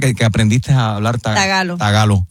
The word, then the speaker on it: tagalo: Espérate, hay un lenguaje que se llama trágalo. – Tagalo. – No, tagalo. – Oh. – No se dice filipino, se dice tagalo.